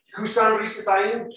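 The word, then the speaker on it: Cushan-rishathaim: He went out to war, and the Lord gave Kushan-Rishathaim, that is how you pronounce it, Kushan-Rishathaim, king of Mesopotamia, into his hand, and his hand prevailed over Kushan-Rishathaim.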